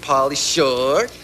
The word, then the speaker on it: Pauly Shore: Third lady said, uh-oh, I got a date with Pauly Shook. – Uh-oh. – No, come on. Say it right. – Uh-oh, got a date with Pauly Shore.